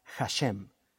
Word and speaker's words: Hashem: Y de hecho en hebreo, el nombre es una frase que se pronuncia Hashem.